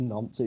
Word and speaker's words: Nantes: He lost to Nantes. – Nantes. – I don't know how you say it. Nantes, Nantes. – No, I think it is nonce.